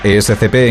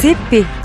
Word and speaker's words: ESCP: Pero, ¿sabes cómo se pronuncia ESCP en cada uno de ellos? ES ESCP.